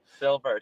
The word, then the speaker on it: Silver: And I am one word, one color, Silver, two syllables.